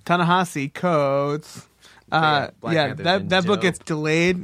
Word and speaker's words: Ta-Nehisi: Ta-Nehisi or Ta-Nehisi? – It's Ta-Nehisi. I'm pretty sure that's the way that I've heard it pronounced in the store.